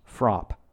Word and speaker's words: FRAPH: The group was called, in English, the Revolutionary Front for Haitian Advancement and Progress, or FRAPH in the French acronym, pronounced FROP.